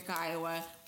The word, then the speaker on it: Kiowa: and then going to Kiowa. Kiowa was like, Wait a minute. – Wait, don't say the name. Oh. I will just tell you it rhymes with Iowa.